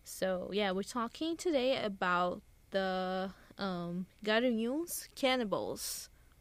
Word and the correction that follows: Garanhuns: I said the name wrong. Garanhuns. It's with an A.